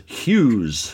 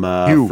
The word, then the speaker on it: Hughes: And it stars Hughes from... – Hugh. – From He pronounces it Hugh. – Oh, okay. It's pronounced, it's spelled Hughes, but pronounced Hugh, from the old lamented Retro League podcast.